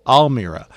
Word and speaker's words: Almira: Is it Elmira? – Almira.